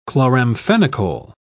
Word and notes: chloramphenicol: British English

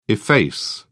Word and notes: efface: British English

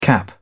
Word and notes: cap: British English

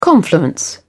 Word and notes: confluence: British English

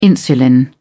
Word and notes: insulin: British English

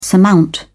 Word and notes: surmount: British English

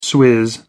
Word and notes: swizz: American English

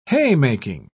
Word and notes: haymaking: British English

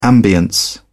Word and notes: ambience: British English

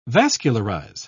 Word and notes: vascularize: British English

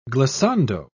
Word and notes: glissando: British English

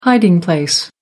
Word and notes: hiding place: American English

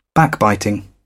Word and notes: backbiting: British English

